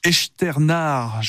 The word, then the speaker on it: Echternach: Ce tableau volé durant la Seconde Guerre mondiale au Luxembourg, dans la commune donc de Echternach, je le dis bien comme vous Thomas Lavaux, ça se trouve à l'est de Luxembourg, ville à la frontière allemande.